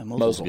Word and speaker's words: Mosul: How do you Mosul. – Use it? – Yeah, Mosul. Good. Yeah. – I always want to say Mosul, but I know that's not right. Mosul.